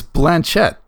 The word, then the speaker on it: Blanchett: I think it's Blanchett.